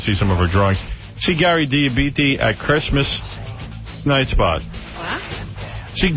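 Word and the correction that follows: Dell'Abate: How say his last name? – Del Abate.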